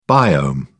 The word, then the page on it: biome: British English